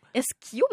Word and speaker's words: eschium: Esquiaux, mais ça s'écrit E-S-C-H-I-U-M.